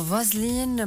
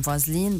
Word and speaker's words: فازلين: وخلال الفيديو نطقت اسم فازلين بطريقة بدت للمتابعين أقرب لبازلين، وهو أمر بيرتبط باختلافات النطق بين اللغتين الإنجليزية والكورية، لأنه بالكورية ما في حرف الفي، بالأحرى ما في، يعني مش موجود صوت الفي، فيصعد عنه غالبا بصوت أقرب للبي.